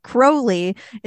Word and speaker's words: Crowley: It's Crowley. It rhymes with slowly.